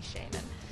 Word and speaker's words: shaman: Give us a little constructive criticism here, and please don't tell me that I'm mispronouncing Shaman. Look it up. It's okay the way I pronounce it.